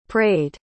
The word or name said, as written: prayed